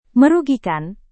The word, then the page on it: merugikan: Indonesian